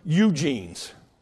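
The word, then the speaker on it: eugenes (εὐγενής): That word noble comes from the Greek word eugenes. Not that Eugene, but eugenes, spelled the same way, E-U-G-E-N-E-S.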